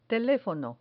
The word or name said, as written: telefono